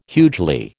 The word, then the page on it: hugely: American English